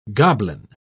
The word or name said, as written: goblin